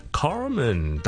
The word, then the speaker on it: Carmen: Today we're going to talk about the pronunciation of 一个英文名 Carmen, C-A-R-M-E-N。咁咧，第一件事，如果你系讲美式英文嘅。一定是要卷你的了 Carmen 小叔卷得比较差，不好意思啊 Carmen。